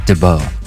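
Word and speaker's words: d'Abo: I believe it's pronounced Dabo. – I think you're right.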